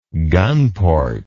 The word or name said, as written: gunport